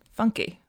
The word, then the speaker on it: Funke: And apparently his last name is literally Funky. – Funke. How'd you spell that? – F-U-N-K-E. – Oh, so it's like Tobias Funke from Arrested Development, but not pronounced that way.